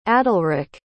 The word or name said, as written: adalric